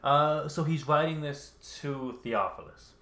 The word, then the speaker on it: Theophilus: In my former book, Theophilus? How do you say that? – Theophilus.